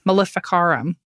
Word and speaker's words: Maleficarum: I forget how to pronounce it, but we talked about it a few episodes back. Maleficarum. You nailed it.